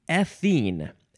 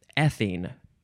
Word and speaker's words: ethene: Next is ethene. Ethene. Emphasize that first syllable.